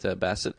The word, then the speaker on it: Bast: Bast. Yeah, like past. – So the last T is silent. – It's not a... – It's past. – Past. – Bast. – It's B-A-S-T-E-T. – Yeah, it's Bast.